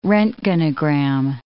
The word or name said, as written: roentgenogram